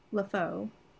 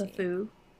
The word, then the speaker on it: LeFou: LeFou.